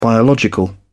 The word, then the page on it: biological: British English